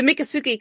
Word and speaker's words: Miccosukee: I hiked how do you say it again? – The Miccosukee Casino.